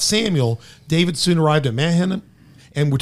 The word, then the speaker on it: Mahanaim: Mahanaim.